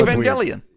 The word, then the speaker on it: Evangelion: It's a hard G. – It's a okay. hard G. I'm sorry. – Okay, fine. – I know. – Evangelion.